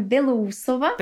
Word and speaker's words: Belousova: Und äh, ja, mein Name ist äh, Patricia und Nachname Belusova.